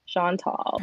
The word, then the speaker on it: Chantel: Yeah, like Sean is tall. Chantel.